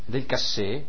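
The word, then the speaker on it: Delcassé: e ministro è un certo Del Cas, qualcosa del genere, Del Cassé, non avevo visto l'accento nella traduzione inglese, un certo Del Cassé, che invece è un anglofilo, no?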